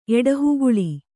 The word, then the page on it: ಎಡಹುಗಲ್ಲು: Kannada